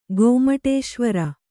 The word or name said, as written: ಗೊಮ್ಮಟೇಶ್ವರ